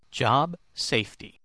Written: job safety

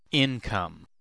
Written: income

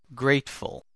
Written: grateful

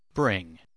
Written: bring

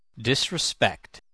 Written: disrespect

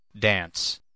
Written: dance

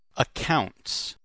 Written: accounts

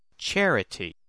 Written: charity

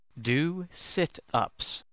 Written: do situps

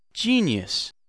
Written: genius

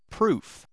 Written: proof

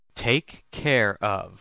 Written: take care of

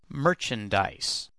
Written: merchandise

